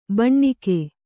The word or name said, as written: ಭೂನಾಗ